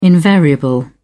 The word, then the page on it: invariable: British English